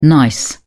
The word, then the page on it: gneiss: British English